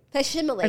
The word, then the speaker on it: facsimile: F-A-C-E-S. No, F-A-C-S-I-M-I-L-E. Fascist smile? – Okay, wait. – Facsimile.